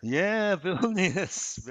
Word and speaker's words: Vilnius: Hopefully, I'm saying it right. Vilnius. – Yeah, Vilnius. Well done.